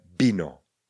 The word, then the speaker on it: vino: Slovo víno, píšeme vino, čteme bino.